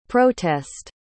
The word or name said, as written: protest